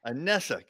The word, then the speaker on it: Anessa: Anessa.